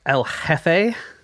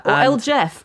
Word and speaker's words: El Jefe: He called himself El Jefe. – Mm-hmm. Or El Jeff, if you want to give it this anglicized pronunciation, which is more funny.